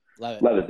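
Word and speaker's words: Leavitt: I can never pronounce his last name. Levitt, I think his name is? – Levitt, there you go.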